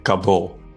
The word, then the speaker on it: COBOL: И не просто программисты, а программисты на языке, который читается как Кабол.